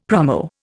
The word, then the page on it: promo: Indian